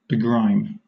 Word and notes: begrime: British English

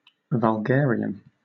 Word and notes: vulgarian: British English

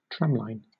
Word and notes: tramline: British English

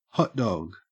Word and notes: hotdog: Australian English

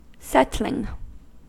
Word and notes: settling: American English

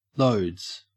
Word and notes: loads: Australian English

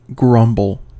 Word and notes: grumble: American English

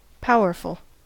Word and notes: powerful: American English

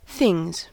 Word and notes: things: American English

